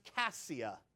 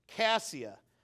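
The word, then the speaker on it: Keziah: He calls his second daughter, it's actually pronounced Cassia. The K sounds like a C, and the Z sounds like an S. Cassia.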